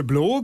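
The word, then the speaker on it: Blø: Und zusammen seid ihr Blo, gesprochen, geschrieben, aber mit einem Strich durchs O, der aber jetzt keine Auswirkungen auf die Aussprache hat, habe ich ja gelernt.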